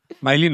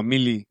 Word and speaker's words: Millie: Miley no, Millie. – Millie.